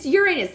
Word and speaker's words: Uranus: And the thing, and it's Uranus, like the planet. – Yes. – Uranus. – Yes. – U-R-A-N-U-S, Uranus.